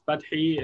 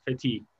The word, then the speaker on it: Fathi: If you want to say it in Arabic, it's Fathi. If we want to say it the American way, it's Fatih.